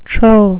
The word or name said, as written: trow